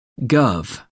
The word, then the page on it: guv: American English